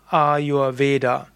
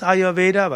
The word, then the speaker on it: Ayurveda: Und die, die Hindi-Aussprache wäre eben Ayurved. Und Ayurved ist also die Hindi-Aussprache, und wenn man dann irgendwo mischt Sanskrit mit Hindi, wie es in anderen indischen Sprachen üblich ist, dann sagt man eben Ayurveda.